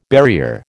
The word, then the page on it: barrier: American English